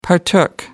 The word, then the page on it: partook: American English